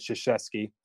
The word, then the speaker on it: Krzyzewski: That's how you say it, Krzyzewski.